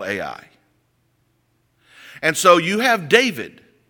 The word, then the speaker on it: Ai: But yet you have one chapter later, and there's a little small village of Ai. Some people pronounce it I.